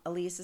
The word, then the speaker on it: Ilise: Elise is spelled I-L-I-S-E.